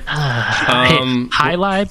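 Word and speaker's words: Jai Alai: Highlight, but for the record, is spelled J-A-I space A-L-A-I, just for the listeners.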